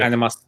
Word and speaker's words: Animas: Animas. – Animas. – Yeah, the animas. – No, not animas. Animas. – Animas. Animas. Animas. It's A-N-I-M-A-S. Mm-hmm. Animas. I keep saying animas.